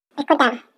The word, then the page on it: pihkutaau: Chisasibi